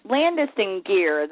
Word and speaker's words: Landis+Gyr: Landis+Gyr, that's how you pronounce it, is that correct? – Yes.